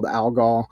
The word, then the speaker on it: Alghol: And then did like the quick Google search, found a few other bands called Alghol. So I stuck a silent H in the middle of mine and called it a day.